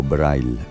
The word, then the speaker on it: Breil: Joseph Carl Breil, o Breil, pronunciado a la alemana, considerado uno de los primeros profesionales de bandas sonoras.